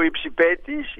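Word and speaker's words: υψιπέτης: Υπάρχει ο υψηπέτης, υπάρχει και ο υψηπετής. Ένας τόνος τα χωρίζει. Ο υψηπέτης είναι από το ύπταμε, ο υψηπέτης αετός, και ο υψηπετής που τονίζεται στη λίγους είναι αυτός που πίπτει.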